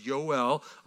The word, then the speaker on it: Yoel: So what we're looking at today, again, is the prophet Joel, is how it's in the Bible. You might see it, but it's pronounced Yoel. I'll try to use Yoel as we go through to help us get in that habit. It's not Jor-El or Kal-El.